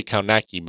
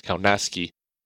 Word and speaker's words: Kownacki: Because a lot of people say Kownacki, but it's Kownacki.